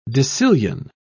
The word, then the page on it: decillion: British English